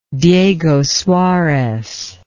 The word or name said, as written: Diego Suarez